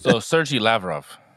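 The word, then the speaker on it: Sergey Lavrov: I think it's, like, Sergio Lavrino. I don't know. – Sergio Lavrov. – There you go.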